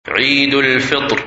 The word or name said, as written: Eid Al Fitr